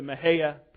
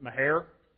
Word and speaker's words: Mexia: I prefer the Mahaya pronunciation. It's somewhat sort of close to how you should say it in Spanish. It's at least a lot closer than the other ways people pronounce it. If you're I don't know, 85 and a redneck, you pronounce it Mahair.